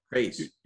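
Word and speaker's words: Kryze: I hope I say this right. Chris, Chris, Chris, Craze. Uh, Craze. Yeah. Bo Katan Craze.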